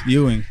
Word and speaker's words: Ewing: I thought he said earrings too. Patrick Ewing. – Ewing. What did I say? – I don't know. Earrings. – My fault. I meant to say Ewing. Ewing. That's how you say it, right? – Ewing.